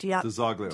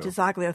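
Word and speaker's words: DiZoglio: DeZoglio. – DeZoglio.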